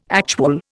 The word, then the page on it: actual: Indian